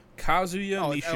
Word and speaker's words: Kazuya Mishima: Kazuya Mishima.